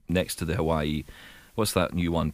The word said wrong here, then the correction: Huawei: But Huawei.